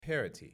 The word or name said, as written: parity